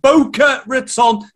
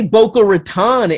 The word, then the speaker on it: Boca Raton: All the action in South Florida, Boca Raton, not Boca. I'm UK pronouncing it there. – And it's funny because I say Boca Raton,